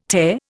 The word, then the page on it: Te: Indian